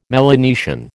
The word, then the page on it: Melanesian: American English